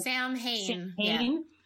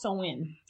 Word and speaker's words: Samhain: So a lot of people mistakenly pronounce it as Samhain. – But it's actually pronounced Samhain.